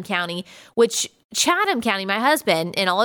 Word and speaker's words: Chatham: I originally pronounced Chatham, and he corrected me. It is Chatham County. I did not realize that. Apparently, it's like a British word, but I did know it's DeKalb because I used to live in Georgia too, and I remember that.